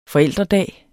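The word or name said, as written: forældredag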